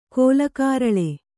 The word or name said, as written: ಕೋಲಕಾರಳೆ